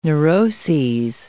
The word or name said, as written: neuroses